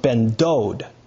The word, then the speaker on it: Ben Dod: But it's actually pronounced Ben Dod, because Dod means uncle, so son of your uncle, Ben Dod, is the same as Ben David.